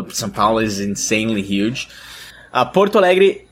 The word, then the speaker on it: Porto Alegre: You are originally from Porto Alegre. Am I saying that right? – Yeah, that's good. – In the south of Brazil.